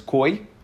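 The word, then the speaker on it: koi (כוי): I think the proper pronunciation is koi, but in yeshivas they say kvi.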